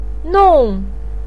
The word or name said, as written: nong4